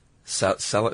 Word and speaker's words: salicylic: And there are also some over-the-counter home remedies that have salicylic. Is how you pronounce that? – Yeah, that's how I salicylic acid as the active ingredient.